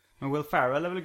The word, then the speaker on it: Ferrell: Jag tror det uttalas Will Farrell.